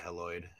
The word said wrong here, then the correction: Heliod: How do I say this? Heloid? – Heliod. – Heliod, okay.